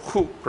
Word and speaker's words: chuk: The Hebrew word chuk, pronounced like hook, not like the name Chuck.